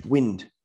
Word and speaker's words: Wind: It's for the fact of saying, and it probably even alludes, goes to the next question from Mitch Wind, rhymes with sins or something along those lines there, where they mentioned, are we underachieving?